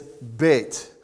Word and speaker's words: Beit: If there is a dot in the middle, it's pronounced B, as in boy. If there is not one, it is pronounced V, as in victor. Okay? But it is Beit.